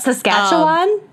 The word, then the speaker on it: Saskatchewan: What, Saskatchewan? – Saskatchewan.